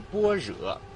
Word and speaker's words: 般若: 那正前方这道大门是法门寺中的第二道大门，它叫做般若门。但是在牌匾上方写的字呢是“ 般若” 两个字，因为这个般若是咱们梵文的音译，写汉字是般若，但念的是古梵文的发音，念的是般若，意思呢是智慧的意思，指的是一种了脱人世间一切大道的一种大智慧。